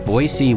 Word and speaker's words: Boise: Our second choice is it's pronounced Boise with an S, not Boise with a Z.